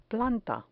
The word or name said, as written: planta